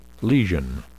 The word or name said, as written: lesion